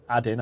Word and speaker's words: Adin: No. Adin. I might be pronouncing it wrong. A-D-I-N. Adin.